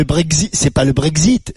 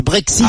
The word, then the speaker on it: Brexit: Dis-moi, pourquoi tu remplaces le X par le Z, toi? – C'est-à-dire? – C'est pas le Brexit, c'est le Brexit.